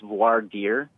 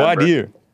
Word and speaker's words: voir dire: And then a second word, D-I-R-E, it's pronounced voir dire. – Okay. Is that really how it's pronounced? – I actually looked it up before. – Oh, really? – How to pronounce it, because I couldn't remember. Voir dire.